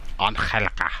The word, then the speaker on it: Angelica: And Angelica. – Angelica. – Silent G. – Yeah, Silent G.